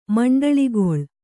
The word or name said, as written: ಮಂಡಳಿಗೊಳ್